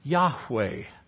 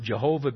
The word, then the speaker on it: Yahweh: So it's YHWH, perhaps best pronounced Yahweh. Yahweh. But we pronounce it, or have heard others pronounce it, Jehovah, because of the influence of German upon our nation's language, where they often substitute J's for Y's and V's for W.